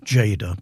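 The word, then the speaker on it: Tjader: That was Cal Jada, and it's spelt in a very unusual way. It's pronounced Jada, but it's spelt with a T, T-J-A-D-E-R, Cal Jada.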